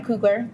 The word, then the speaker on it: Coogler: Is it Coogler? – I don't know. Uh, Coogler. Coogler. Okay. Black Panther Wakanda Forever was directed by Ryan Coogler. Did I say that right? yeah, no, you're good.